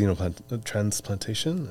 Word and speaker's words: xenotransplantation: That's fascinating, especially with the xenotransplantation.